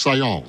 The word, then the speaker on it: Saillant: So Kiana, as I just spelled it, K-I-A-N-N-A, and then S-A-I-L-L-A-N-T, but pronounced Sion.